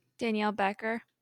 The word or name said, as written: Becker